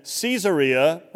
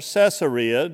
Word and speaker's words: Caesarea: Well, Jesus and his disciples had been in Caesarea, or Caesarea, depending on who pronounces it, Caesarea Philippi.